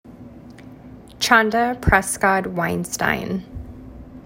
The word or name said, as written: Chanda Prescod-Weinstein